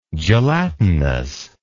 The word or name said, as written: gelatinity